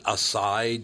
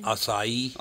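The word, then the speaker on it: açaí: You know, in this next story, I really hope this woman wasn't – what is it? Acai juice? Is that how you say it? – Acai. – I just say acai. It's easier. – I hope this woman wasn't named after that because her name is Acaime. A-C-A-I-M-I-E.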